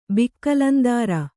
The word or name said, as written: ಬಿಕ್ಲಂದಾರ